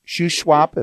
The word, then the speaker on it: Shuswap: My producer, I mentioned earlier, Larry Wolf, at that point, we're looking at the salmon in the lake you mentioned, Shuswap, if I'm saying that right.